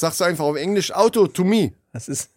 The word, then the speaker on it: Autotomie: Autonomie, Auto. Ah, doch, du hast es nur falsch ausgesprochen. Das heißt eigentlich Autotomie.